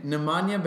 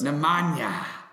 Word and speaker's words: Nemanja: How do you say his first name? – Nemanja Bjelica. – Nemanja. I like Nemanja.